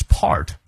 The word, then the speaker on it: part: It is part 重音在前，英式发音当中啊，而不卷舌。